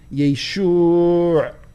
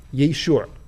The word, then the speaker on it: Yeshua: Shoe, the thing you wear on your feet, Yeshua. And so at the end, it's this, Yeshua, was the Galilean Aramaic way of pronouncing the name of Jesus, peace be upon him.